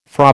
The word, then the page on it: Fra: American English